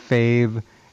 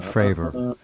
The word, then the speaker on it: Fravor: It's actually not Fave. It's Fravor, which is even stranger.